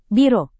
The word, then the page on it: biro: Indonesian